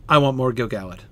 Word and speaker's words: Gil-galad: No, Gil-galad is how it's supposed to be pronounced.